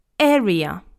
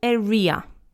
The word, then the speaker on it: area: Area. It's not area, okay? You need to remember that the stress is on the first syllable, area.